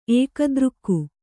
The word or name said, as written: ಏಕದೃಕ್ಕು